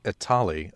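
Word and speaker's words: Etali: The name is pronounced Itali according to Wizards of the Coast.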